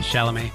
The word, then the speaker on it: Chalamet: In actual theatres, Wonka, the Timothy Chalamet, how do you pronounce his name? – Chalamet.